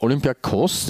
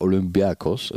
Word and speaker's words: Olympiakos: Ähm, und Olympiakos, das ist auch die tatsächlich äh, korrekte griechische Aussprache auf die Endbetonung, also eigentlich ist, ist es sogar Olympiakos, also mit welchem B, mhm.